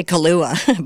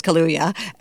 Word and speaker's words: Kaluuya: Daniel, I always say Kaluuya, but it's Kaluuya.